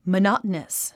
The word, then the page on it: monotonous: mənάt ə nəs; マナタナス